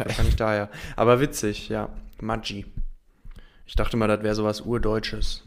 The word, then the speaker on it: Maggi: Das äh, ist eigentlich was Italienisches. Und deswegen wird es eigentlich Maggi ausgesprochen.